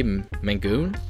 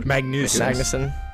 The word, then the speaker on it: Magnussen: Billy Magoon? – Magnussen.